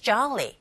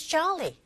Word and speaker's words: jolly: Every second heading to is jolly. 네. 졸리보다는 좌, 아에 가깝게. 오 사운드, 즉, 오 철자에 강세가 가게 되면 아에 가깝게 발음하시면 됩니다. 그렇게 들리고요. Go listening to. Every second heading to is jolly.